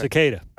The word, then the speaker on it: cicada: That's the name of it, right? Am I saying it right? – Cicada. – Cicada. Let's go with... So cicadas is the correct word.